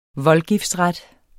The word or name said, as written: Voldgiftsret